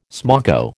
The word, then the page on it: smoko: American English